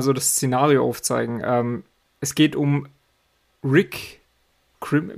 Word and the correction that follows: Grimes: wie spricht man seinen Namen Grimes.